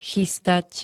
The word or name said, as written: chystať